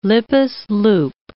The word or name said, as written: Lippes loop